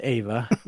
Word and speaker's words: Eva: This comes to us from Eva. Ava, sorry. My name is Ava, and she said it's pronounced Ava, even though it's spelled Eva.